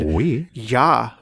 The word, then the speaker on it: Ouija: of the word oui, which is French for yes, and oui. – Ja, which is German for yes. – Oh, so that's simple. – Ouija. You have to say it like that with the accent.